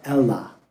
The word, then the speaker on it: Elah: In Hebrew, it's actually pronounced, when you read the scriptures and do it on Esword, you could see in Hebrew, in Aramaic. The terebinth tree was called the Elah.